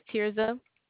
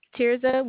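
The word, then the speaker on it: Tirzah: My name is Tirza. – How do you say that once more? – Tirza, would you like for me to spell it? – Yeah.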